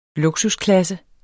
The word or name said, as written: luksusklasse